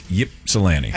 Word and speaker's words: Ypsilanti: Well, I know it's not Ysplanti. – It's Ypsilanti. – I need to see how it's spelled again. I've always called it Ysplanti.